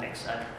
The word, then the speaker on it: Linux: They use Linux, I think, L-I-N-U-X. Yeah, that sounds right. Linux. I'm not familiar with it, so I had to ask how you pronounce it. It's Linux.